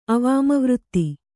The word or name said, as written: ಅವಾಮವೃತ್ತಿ